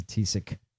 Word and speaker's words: Taoiseach: I know how this is pronounced, but it's in Gaelic. It's Taoiseach. – It's been that's Taoiseach. – Yes. If you look at it phonetically in English, it's Taoiseach. Taoiseach. Taoiseach.